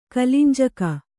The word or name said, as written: ಕಿಲಿಂಜಕ